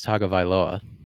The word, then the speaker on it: Tagovailoa: I believe it's Tagovailoa. – Tagovailoa. I got to practice that. But It's easier than Albert O, but I still could be pronouncing it a little bit incorrect, but I believe I have it right. – It seems right.